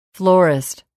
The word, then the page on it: florist: [flɔ́ːrist]